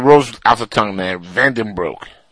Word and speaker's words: Van Den Broek: Yeah, I you know, you got to say it as it's actually pronounced. Not like, you know, an American would say, which would be like Van Danbroek.